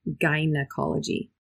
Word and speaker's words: gynecologist: If you see G-Y-N, we're going to pronounce that G, gynecology.